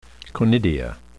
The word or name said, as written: conidia